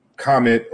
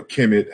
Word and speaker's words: Kemet: So Kam, meaning Kamet or Kemet, however you want to pronounce it, we call it Chikam.